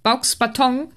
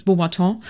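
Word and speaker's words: Beauxbatons: die ich als Kind Baux-Baton natürlich ausgesprochen habe. Es ist Beaubaton.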